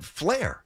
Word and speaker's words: Fleer: How do you say it? Like Fleer. – Fleer. – F-L-E-E-R. – I know how to spell it. Flair.